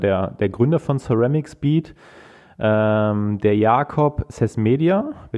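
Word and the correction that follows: Csizmadia: wenn ich es richtig ausspreche, ist das richtig? – Ja, wir sind dicht dran. Das ist das, der ist der Jakob Sismadia. Sismadia, okay.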